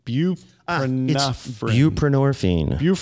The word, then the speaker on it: Buprenorphine: Buprenorphine.